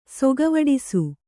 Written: ಸೊಗವಡಿಸು

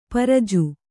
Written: ಪರಿಜು

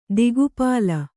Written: ದಿಕ್ಪಾಲ

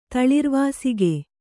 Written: ತಳಿರ್ವಾಸಿಕೆ

